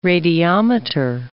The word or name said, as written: radiometer